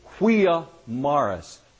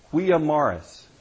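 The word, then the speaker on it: via maris: In Latin, that phrase is quia maris, quia maris, M-A-R-I-S. Huia, spelt with a V-I-A. Huia Maris.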